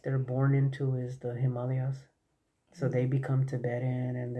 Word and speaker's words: Himalayas: David Wilcox says that it's pronounced Himalayas. Kind of like Brazil spelled with an S, but People spell it with a Z. It's Himalayas.